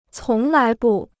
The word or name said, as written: 从来不